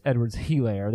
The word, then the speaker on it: Edwards-Helaire: Well, first of all, I said it wrong. It's Edwards-Helaire. There's an H in there. I don't want to perpetuate a mispronunciation.